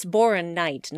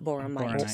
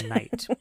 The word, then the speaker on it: Boronite: Okay, it's Boronite, not Boromite. Sorry, guys. – Boronite.